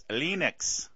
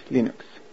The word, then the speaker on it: Linux: But Linus Torvalds pronounces Linux, Linux. Let's hear that again. – Hello, this is Linus Torvalds, and I pronounce Linux as Linux.